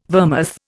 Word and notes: vermouth: Indian

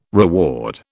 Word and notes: reward: British English

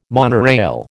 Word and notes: monorail: American English

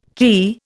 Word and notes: kea: Indian

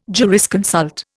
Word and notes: jurisconsult: Indian

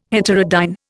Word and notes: heterodyne: Indian

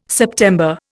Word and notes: September: Indian

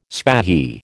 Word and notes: spahi: American English

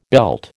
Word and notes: belt: American English

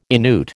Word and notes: Inuit: American English

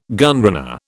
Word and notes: gunrunner: British English

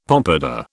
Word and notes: pompadour: British English

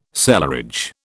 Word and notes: cellarage: British English

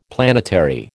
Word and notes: planetary: American English

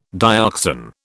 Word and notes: dioxan: British English

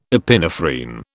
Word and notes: epinephrine: British English

